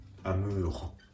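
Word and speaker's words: amour: アムーホ、アムーホです。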